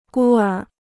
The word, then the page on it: 孤儿: gū ér